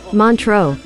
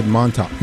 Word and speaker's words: Montreux: Montreux. – Spell it for us. – It is spelled M-O-N-T-R-E-U-X. – And how did you say it? – And I said Montox.